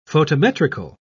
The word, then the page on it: photometrical: British English